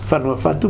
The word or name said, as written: Fanua Fatu